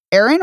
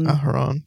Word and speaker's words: Aharon: Aaron or Aharon? – Aharon, yeah, probably.